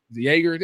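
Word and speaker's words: Joerger: David Yeager. – Yeager, there you go.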